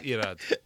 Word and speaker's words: Irad: It's Irad. – Irad. – Irad. – I'm actually with him. – You got to roll the R's, Irad.